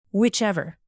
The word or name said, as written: whichever